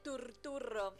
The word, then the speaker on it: Turturro: No sé si se recordarán que de esa época vi que se dice turturro. – John Turturro. – Se pronuncia así, no es que lo estoy pronunciando para el culo. No, se pronuncia así porque lo dijo el propio John Turturro.